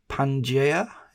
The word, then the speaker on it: Pangaea: Divergence began perhaps about 150 million years ago with the fracture of, now you're going to have to tell me how to pronounce this because I'm going to stumble, I think, the fracture of Pangaea, is that right? – That sounds pretty good to me.